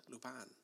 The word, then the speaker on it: Lupin: Let's say it properly, man. It's French. Lupin. – Lupin. – Not Lupin.